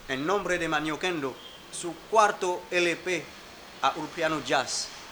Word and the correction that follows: Díaz: Pero el apellido, ¿cómo se escribe el apellido? – D-I-A-Z. – Ah, Ulpiano Díaz. D-I-A-Z. Ulpiano Jazz, no, Díaz. – Díaz.